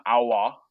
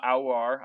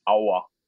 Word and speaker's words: Aouar: Apparently you like basically don't pronounce the one consonant that's in his last name, just Aouar, and that's it.